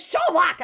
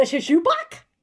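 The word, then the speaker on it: Sjoelbak: He's like, it's Schoolbak. And I'm like, the Shishubak? The Shishubu? Right? Like, just it makes him crazy.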